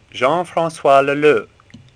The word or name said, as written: Leleu